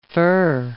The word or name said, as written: fir